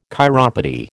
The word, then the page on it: chiropody: American English